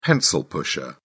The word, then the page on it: pencil pusher: British English